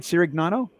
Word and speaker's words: Cirignano: So as we continue our discussion here with Doug, and did I say it right, Cirignano? – Yeah, you got it pretty good.